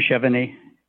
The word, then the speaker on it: Chevigny: Jim Chevegni. It's spelled C-H-E-V-I-G-N-Y.